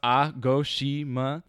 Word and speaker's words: Agashima: And Agashima Island, pronounced Agoshima.